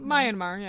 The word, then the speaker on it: Myanmar: Myanmar. – Myanmar, yeah.